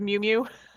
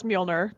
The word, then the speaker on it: Mjolnir: So why don't we start with Mjolnir, which is Thor's hammer, which hilariously Kat Dennings, who plays Darcy in the movie, continually refers to as Mew Mew. She can't pronounce it. But I'll try to pronounce Mjolnir correctly.